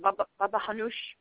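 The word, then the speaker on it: baba ghanoush: Yeah, it's not baba ga. People pronounce it wrong. You don't pronounce the actual letters. It's more like a baba hanoush, baba ganoush.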